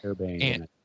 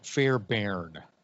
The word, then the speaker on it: Fairbairn: Fairbairn. – Okay. – It's Fair with AI and Bairn, BAI, Fairbairn.